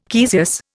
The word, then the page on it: caesious: Indian